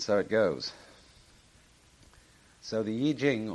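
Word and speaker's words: Yi Jing (易經): called the Yi Jing, not the Wai Ching.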